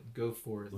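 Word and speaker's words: Geaux Forth: Yeah, so Gox Forth. G-E-A-U-X. The Cajun spelling. – Go Forth. – Louisiana style. Mm.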